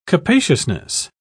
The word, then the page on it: capaciousness: British English